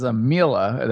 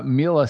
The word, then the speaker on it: Miele: So it's like, if you want to check it out, it's the brand is Miele. That's spelled M-I-E-L-E.